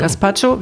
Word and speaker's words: Gazpacho: Er machte Gazpacho, so habe ich das richtig gesagt? – Gazpacho, ja. – Gazpacho, wir schauten Breaking Bad und plötzlich lehnte er sich an mich, so als ob es das Normalste der Welt wäre.